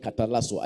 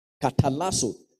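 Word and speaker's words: katalasso (καταλλάσσω): Katalasso. It is spelled K A T A double L A S S O, katalasso.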